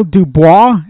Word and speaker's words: Dubois: Daniel Dubois, and I hope I'm saying that correctly.